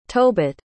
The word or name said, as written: tobit